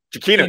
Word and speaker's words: Giacchino: Giacchino, yes.